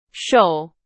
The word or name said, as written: 守